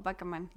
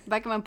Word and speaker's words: Bachman: Sí, creo que se pronuncia como Bachman. No sé, nunca es... – Bachman. – Bachman, porque después crea Bachmanity. – Bachmanity, sí, tienes razón. – Sí, debe ser Bachman.